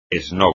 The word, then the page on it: esnob: espagnol